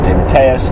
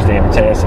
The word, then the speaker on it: DeMatteis: Somebody asked him how to pronounce his name. He said DeMatteis. He's DeMatteis. He prefers DeMatteis.